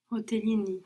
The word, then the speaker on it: hotelini: Hotellini. – Hotellini. So I stay right here in the hotel. Ka. – Nina kaha. – You don't have to break the syllables, Okay.